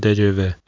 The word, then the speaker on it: TGV: 最快速的高铁是二零零七年的今天四月三号，法国的高铁 TGV，或是法国人是念成 TGV。